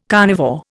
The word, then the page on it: carnivore: Indian